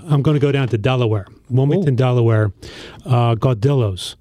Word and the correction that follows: Gaudiello's: Gaudiello's. I probably pronounce it wrong every other time, but G-A-U-D-I-E-L-L-O, apostrophe S, Gaudiello's, Trolley Square,